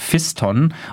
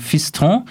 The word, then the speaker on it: Fiston: Wir kommen nun zu einem Buch, das mir wurde es geflüstert von französischkundigen Menschen, nicht Fiston oder anderweitig ausgesprochen, sondern Fiston, Vansa Mujila möglicherweise und sein Buch Tanz der Teufel.